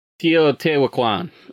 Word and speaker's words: Teotihuacan: Teotihuacan.